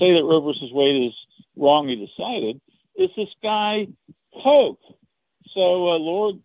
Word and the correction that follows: Coke: Cook, so or Cook, it's pronounced Cook. Sorry, it's spelled Coke.